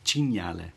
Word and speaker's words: cinghiale: cinghiale. – Yeah. – Is that right? – No, but it's really close. Cinghiale, yeah. But you're very close, yeah.